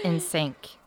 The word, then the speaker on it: NSYNC: That's more egregious than that kid on TikTok that pronounced it NSYNC. – Oh, my God. This is a bot by NSYNC. I'm sorry. It's in sync.